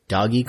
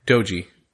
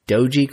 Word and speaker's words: Doge: I want to ask you, did you hear about that like Doggy coin? Doge. Doge coin? Is that how you say it? I don't even know. I've only well, read it. Doggy would be with two G's.